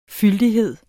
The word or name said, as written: fyldighed